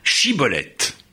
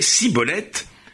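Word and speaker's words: shibboleth: il lui disait Prononce donc Chibolette. Et il prononçait Cibolette, car il ne se réussissait pas à parler correctement.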